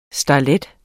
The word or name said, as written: starlet